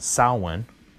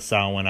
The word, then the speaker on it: Samhain: And then I remember hearing about his second band called Samhain, which I believe is the proper way to pronounce it. I think it's its proper Gaelic pronunciation. And it's spelled S-A-M-H-A-I.